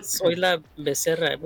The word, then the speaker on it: Soyla Becerra: I had a friend in but her name was Soyla Becerra, which in Spanish is translated "I'm the cow." – The whole name. – How do you say it? – Soy la becerra.